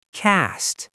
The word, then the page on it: cast: American English